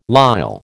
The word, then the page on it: lisle: American English